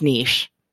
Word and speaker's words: niche: My buddy Mikey told me that niche rhymes with quiche. So if you're ever wondering, is it niche, or is it niche? It's niche, rhymes with quiche.